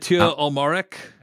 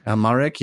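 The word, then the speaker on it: Amalric: Almaric. – Almaric, yeah, from The Diving Bell and the Butterfly.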